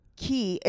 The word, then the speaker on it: Kye: Ki. It's spelled K-Y-E.